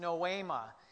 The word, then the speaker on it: noema: For the enemy's lies are so effective that there's actually a Greek word for it, and it's pronounced noema.